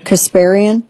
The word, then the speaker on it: Kasparian: So I don't think I'm going to say her name right. Anna Kasparian. – Kasparian.